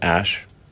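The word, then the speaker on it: Æ: The AE is like pronounced Ash.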